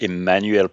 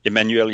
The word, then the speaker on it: Emmanuel: My LinkedIn profile is very detailed, and I'm simply at Emmanuel Probst. Emmanuel is spelled with two M's, E-M-M-A-N-U-E-L, and my last name is Probst, P-R-O-B as in boy, S T.